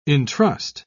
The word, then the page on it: intrust: British English